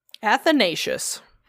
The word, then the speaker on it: Athanasius: Athanasius? That's how you say it. Athanasius. – There's no S-H, and that's really weird. – It's S-I-U-S makes the Shay sound.